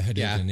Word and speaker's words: Hadouken: It's basically a Hadouken, if I'm saying that right. – Yeah. – Yeah, Hadouken, yeah.